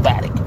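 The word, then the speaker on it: Batiuk: It's pronounced Batic.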